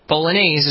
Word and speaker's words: bolognese: Like bologna. It's bolognese is from Bologna.